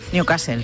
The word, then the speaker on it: Newcastle: Continúa el goteo de focos de la enfermedad de Newcastle en, lo he dicho bien. – Newcastle, sí, César.